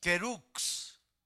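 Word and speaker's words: kerux (κῆρυξ): La palabra pregonero también en griego, mis hermanos, viene una palabra que se pronuncia kerux, que significa predicador, anunciador, un heraldo.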